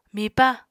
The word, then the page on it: mipa: Apatani